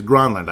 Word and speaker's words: Granlund: And everybody's like, it's Granlund. And he said, no, it is Granlund.